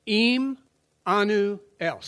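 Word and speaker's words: Immanuel: Im anu el. Say it fast. Im anu el. Im anu el. We pronounce it Emmanuel.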